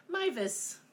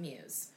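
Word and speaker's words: Meiwes: So, today's case is about the Rottenburg Cannibal, or, you know, his name, which is Armin Mivus. – Not Muse. – Not, it looks like Muse. – It looks like Muse. – But that's why I Google how to pronounce it, and I listen to it, because otherwise I would have said it wrong.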